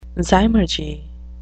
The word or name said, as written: zymurgy